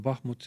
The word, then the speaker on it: Bahmut: See sama Bahmut, mida me nüüd igapäev uudistes kuuleme, või seda hääldatakse ka Bahmut, see on ukraina keeles ka kahe rõhuga.